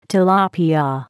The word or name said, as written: tilapia